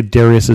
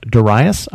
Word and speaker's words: Darius: I should say Darius is also pronounced Darius.